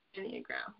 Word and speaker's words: Enneagram: you're doing an amazing job, but it's, I mean, I feel like going back to your, how do you say it, Enneagram? – Enneagram, yeah.